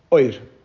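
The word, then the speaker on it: oir (אור): At times, when we're speaking about godly energy, we refer to it as light, oir, like the oir ein soif. In my Ashkenazic pronunciation, pronounce it any way you want.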